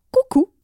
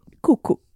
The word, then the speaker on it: Kuku: On les a vus à la Fashion Week, mais aussi à Coucou. C'est le nouveau resto des stars dans le 8e arrondissement. – Ça s'écrit KUKU. – Oui, Coucou ils ont euh, dîné à côté de Leonardo DiCaprio.